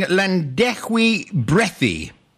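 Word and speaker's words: Llanddewi Brefi: And this village, am I correct in pronouncing Llandechwy Brethi? – That'll do. The locals will certainly identify it.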